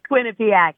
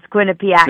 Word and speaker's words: Quinnipiac: Quinnipiac. – Thank you. – It's all right. I'm closer to it. It's Quinnipiac.